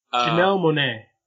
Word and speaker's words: Janelle Monáe: Janelle Monáe.